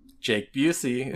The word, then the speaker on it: Jake Busey: Jake Busey, is that right? – Jake Busey, correct.